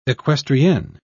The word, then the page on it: equestrienne: British English